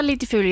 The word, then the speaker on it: Litfulo: Sedan den 1 juni här i år så ingår ju läkemedlet Litfulo, om jag uttalar det korrekt. – Ja, Litfulo, ja.